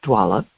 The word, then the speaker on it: doula: NTSB consultant and end-of-life doula. How do I say that? – Doula. – Doula, okay.